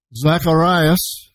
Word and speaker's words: Zacharias: Zacharias, as it's pronounced in the Greek in Luke chapter 1, perfect example, the one that I always use.